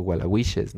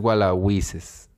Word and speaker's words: Gualahuises: se llama Gualahuises, o Gualahuiches, no sé cómo, que ni siquiera se pronuncia, me, me olvidó, pero es Gualahuises, que es como una región así de Nuevo León, pues como un municipio así alejado.